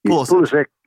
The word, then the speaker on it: Pulisic: Pulisic.